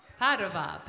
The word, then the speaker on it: Padova: All right, do you guys watch MMA here in Padova? Is that how you pronounce it? Padova? Padova, Padova.